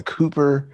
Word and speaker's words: Kuiper: The Kuiper Project. I think that's how you pronounce that. K-U-I-P-E-R.